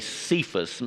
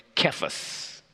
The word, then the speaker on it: Cephas: But in the Aramaic, the language that Jesus spoke, the word is, we say Cephas. Maybe some of you have heard it pronounced Kephas. C or K, what is it? Kephas, Cephas. Another of his name,